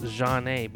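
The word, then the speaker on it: Zhané: This spent 13 weeks on the chart, peaking at number 16 from their platinum album, which was titled, pronounced Jeanne, because it's spelled Z-H-A-N-E with an accent.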